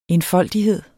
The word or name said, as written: enfoldighed